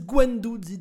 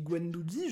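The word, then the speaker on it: Guendouzi: Il y a des grands spécialistes de la planète football comme Eric Carrière qui disent Gwendouzi. Donc arrêtez de m'emmerder parce que je dis Gwendouzi, je suis pas le seul.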